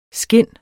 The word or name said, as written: skin